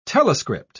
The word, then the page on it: telescript: British English